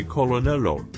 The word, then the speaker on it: colonel: They replaced the R in coronel with an L, but kept the R sound and dropped the pronunciation of the second L and pronounced it colonel as in colonel.